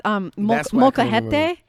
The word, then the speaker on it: molcajete: They have molcajete. Yeah. Is that how you say it? – Molcajete, my gosh.